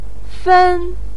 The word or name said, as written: fen1